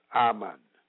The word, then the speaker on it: aman: The Hebrew word for established is aman, pronounced aman,